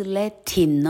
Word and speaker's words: Latin: 山 mountain，泉水 fountain，好，船长 captain，啊，拉丁文 Latin，Latin，而不是 Latin 哦，是念作 Latin。